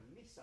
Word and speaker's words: Nissan: 英語とかドイツ語で言ったら Nissan。 Nissan? いや、 Nissan だね。これ Nissan って発音するんで、 Nissan。